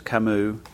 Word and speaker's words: Camus: The proper pronunciation of his name is Camus.